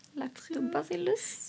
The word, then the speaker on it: Lactobacillus: Eh, men det är faktiskt så att en av de viktigaste bakterierna i slidan heter lactobas Sillus. – Lactobacillus. – Jag tror det uttalas ja, så.